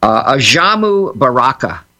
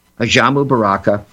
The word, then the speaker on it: Ajamu Baraka: Ajamu Baraka. Ajamu Baraka.